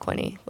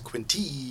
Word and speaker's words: Quinte: That it's pronounced Quinte. – Even though Siri says it funny. – Yeah, Doug said Quint earlier, and that's sort of our running joke that, like, people call us Quint.